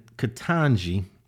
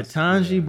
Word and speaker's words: Ketanji: I want to say congratulations to K- K- Ketanji. Is that how you pronounce it? Yeah, yeah Ketanji Brown Jackson the first black woman in 200 and I don't know 250 years to be on the U.S.